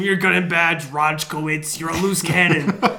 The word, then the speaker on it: Rojcewicz: Rojkiewicz. – Rojkiewicz. – R O J C E W I C Z. – Oh. Rojkiewicz.